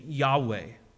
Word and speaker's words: Yahweh: It translates out to be the pronunciation Yahweh.